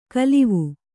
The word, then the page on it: ಕಲಿವು: kalivu